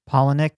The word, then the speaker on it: Palahniuk: I think it's Palahniuk. – Palahniuk, yeah, Chuck Palahniuk, the writer of Fight Club, the novel.